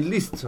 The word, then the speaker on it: Liszt: Voi siete la patria dei Liszt, no? Che era. Non so come pronunciarlo. L-I-S-Z-T. Liszt.